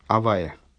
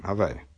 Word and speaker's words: Авая: Кстати говоря, исходная цитата, исходный стих произносится Авая, не Элейким, Авая.